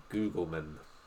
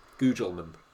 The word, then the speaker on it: Gugelmann: And Mauricio Gugelmann. – Oh, can I Google that? – Sorry, I didn't quite pronounce that right, did I? Gugelmann.